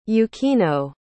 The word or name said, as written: Yukino